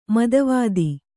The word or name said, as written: ಮೇಧಾವಿತೆ